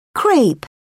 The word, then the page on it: crepe: British English